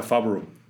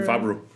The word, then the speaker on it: Favreau: Eh, pero dijo que se pronuncia Fabro. – Entonces, ah, ah ok, John Fabro.